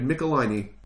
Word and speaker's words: Michelinie: Because they said Pickle my knee. So it would be Michelinie, not Michelinie.